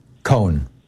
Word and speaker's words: Cohn: Then finally McCarthy and Ray, you said Ray Kahn or Ray Cohen? – Cohen. One syllable. – Cohen. Okay. Cohen. C-O-H-N.